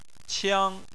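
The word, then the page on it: qiang: tone 1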